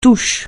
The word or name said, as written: touche